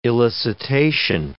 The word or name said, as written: elicitation